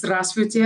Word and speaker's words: здравствуйте: The way they say hello is «здравствуйте», and it's like four syllables.